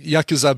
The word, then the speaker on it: Yakuza: Yes, And I've been saying Yakuza because typically in Japanese language, you don't emphasize any syllables. It's not like English where you emphasize a syllable or Spanish where you emphasize the second to last syllable or things like that.